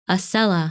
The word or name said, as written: Ascella